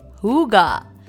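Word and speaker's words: hygge: इसकी स्पेलिंग थोड़ी अलग है but it is called हुगा so the spelling is H Y जी जी ई अब आप सोच रहे होंगे इसको हुगा क्यों कहते हैं Well, this is a Danish concept and it is pronounced in a Danish way.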